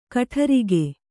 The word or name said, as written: ಕೊಠಾರಿಕೆ